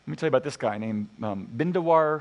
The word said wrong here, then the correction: Bindeshwar Pathak: Let me get his name properly. Wait for it. there it is. Bindeshwar Pathak